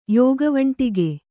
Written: ಯೋಗವಂಟಿಗೆ

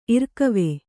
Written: ಇರ್ಕಿವಿ

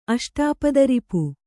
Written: ಅಷ್ಟಾಪದರಿಪು

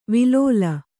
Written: ವಿಲೋಲ

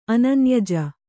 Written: ಅನನ್ಯಜ

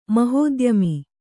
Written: ಮಹೋದ್ಯಮಿ

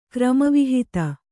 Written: ಕ್ರಮವಿಹಿತ

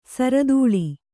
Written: ಸಿರತಾಳಿ

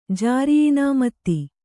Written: ಜಾರಿಯಿನಾಮತಿ

